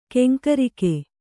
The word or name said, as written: ಕೆಂಕರಿಕೆ